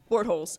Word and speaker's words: Bortles: Bortles.